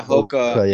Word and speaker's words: Hoka: Is that how you pronounce it? I don't even know. Yeah, Hoka.